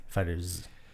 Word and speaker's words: FighterZ: FighterZ. I'm trying to pronounce it as like one word. I'm not trying to split it up.